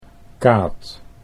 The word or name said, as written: Kaat